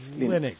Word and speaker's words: Linux: Hello, this is Linus Torvalds, and I pronounce Linux as Linux.